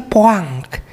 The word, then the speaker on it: point: Mereka bacanya poang. Kalau kita nggak pernah belajar bahasa Perancis, oh jadi dari poang mana gitu. Padahal ternyata gitu. INT, ENT itu maksudnya jadi ang gitu kan, antara jadi poang, poeng atau apalah, pokoknya pasti jadi ang gitu.